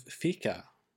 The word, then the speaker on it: fika: Sweden, the concept of fika, I think that's how you say it, F-I-K-A.